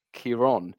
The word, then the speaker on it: Ciarán: However, his name does not have a fother, which is an accent on the second A. And this storm does, which in theory creates a different sound. It tends to elongate the vowel sound. And so rather than Ciarán, it's Ciarán.